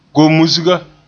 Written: gomusga

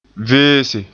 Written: veese

